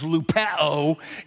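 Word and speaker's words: lupeo (λυπέω): It sounds like the disease lupus. It's actually pronounced that way. And what lupus means, lupeo in Greek, is intense internal pain.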